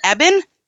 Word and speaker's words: Eben: Is it Eben? – It is. Good job. – Well, I learned from you, so thank you. – Oh, okay. Eben Ice Caves, yeah.